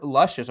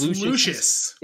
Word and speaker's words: Lucius: Here we have Luscious, our guy. Is this Luscious? It's Lucius. – I like calling him Luscious. – You can call him Luscious, but the proper pronunciation is Lucius.